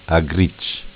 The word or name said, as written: A gricc